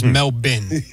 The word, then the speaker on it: Melbourne: The Australian pronunciation, Sal, is Melbourne.